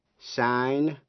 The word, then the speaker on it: sign: Sign, signature. Âm G trong chữ sign come, nhưng phát âm trong chữ signature, danh từ. Trong chữ sign, S-I-G-N, nghĩa là ký tên, âm G come, không phát âm.